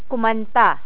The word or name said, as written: kumanta